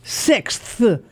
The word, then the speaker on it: sixth: Put your tongue between your front teeth and blow. Sixth.